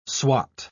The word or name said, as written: swat